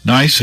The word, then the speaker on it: gneiss: Gneiss is spelled G-N-E-I-S-S, but is pronounced gneiss, and white streaks of quartz and feldspar.